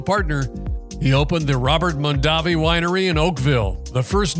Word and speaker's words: Mondavi: Peter said Mondavi, the Americanized version their father had used. Robert switched to Mondavi, the traditional Italian pronunciation.